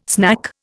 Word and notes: snack: Indian

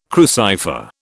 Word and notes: crucifer: British English

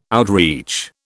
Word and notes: outreach: British English

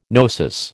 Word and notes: gnosis: American English

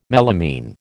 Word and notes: melamine: American English

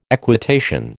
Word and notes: equitation: American English

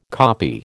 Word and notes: copy: American English

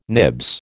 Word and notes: nibs: American English

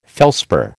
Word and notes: felspar: American English

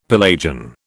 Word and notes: Pelagian: British English

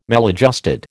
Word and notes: maladjusted: American English

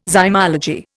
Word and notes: zymology: Indian

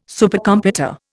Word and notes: Supercomputer: Indian

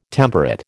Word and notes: temperate: American English

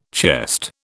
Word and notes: chest: British English

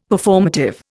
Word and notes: Performative: Indian

